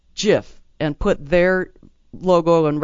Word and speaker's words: GIF: A multicolored GIF flashing the same phrase. So it's pronounced GIF, even though it's spelled GIF.